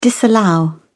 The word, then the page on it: disallow: British English